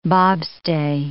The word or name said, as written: bobstay